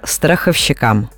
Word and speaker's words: страховщик: Специально не говорю, чтобы даже не звучал неправильный вариант, а правильно страховщик. Страховщик, и ударение остается на корне страховщиком, только так.